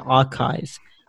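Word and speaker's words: arXiv: Astronomy has this fantastic website called Archive, where anyone, it's spelled A-R-X-I-V. A-R-X-I-V, yeah.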